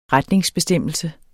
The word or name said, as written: retningsbestemmelse